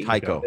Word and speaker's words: Taika: Taika, yeah.